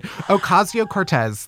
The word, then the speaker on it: Ocasio-Cortez: Ocasio-Cortez.